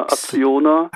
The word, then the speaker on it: Acciona: Dann ist ein Wert drin, wo ich mir nicht sicher bin, wie der korrekt ausgesprochen wird. A-C-C-I-O-N-A. – Ja, Acciona. Acciona, okay.